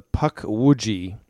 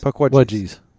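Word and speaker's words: Pukwudgie: Puckwudgies. – Puckwudgie. – Like it's a wedgie. It's the same puck. Rhymes. Puck. It's puck and wudge.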